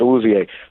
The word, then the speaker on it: Awuzie: So he has the capability, but here's what's going to make Hooker and Elijah Moore better: Snead and Awuzie. Did I pronounce the name right? – Yeah. – Okay, Awuzie. With Snead and Awuzie, those guys are going to make up.